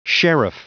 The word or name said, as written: sheriff